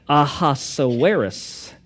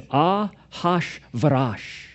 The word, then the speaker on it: Ahasuerus: Because when you first look at that word, you're like, Ahasuerus. But that's not how you say it. This is a Hebrew word. And it's Ahashvarash.